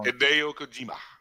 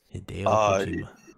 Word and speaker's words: Hideo Kojima: Hideo Kojima. – Yes. – Hideo Kojima.